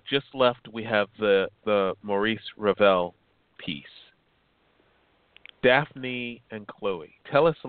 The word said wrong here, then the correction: Daphnis: Since it's Greek, it's pronounced Daphnis. We do say the last N. It's Daphnis and Chloe.